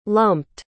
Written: lumped

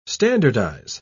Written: standardize

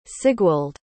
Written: sigwald

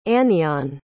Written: anyon

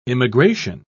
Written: immigration

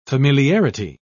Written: familiarity

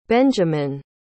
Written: benjaman

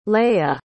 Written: leya

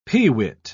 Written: peewit

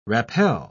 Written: rappel

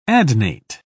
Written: adnate

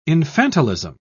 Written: infantilism

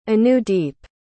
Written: anudeep

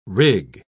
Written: rig

